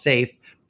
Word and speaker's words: SAIF: Back then, there was a data format called Spatial Archive and Interchange Format, or SAIF, pronounced SAFE.